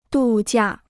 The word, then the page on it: 度假: dù jià